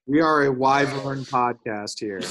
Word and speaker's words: Wyvern: What are the Wyvern. Wyvern. That's how you say it. Wyvern.